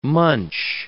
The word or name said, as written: munch